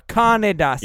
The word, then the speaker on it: Kaneda: I heard Kanda for some reason. I don't know why. – Sorry, Kaneda. Sorry, yeah. It's three syllables.